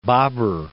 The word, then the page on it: Babur: Ba.bur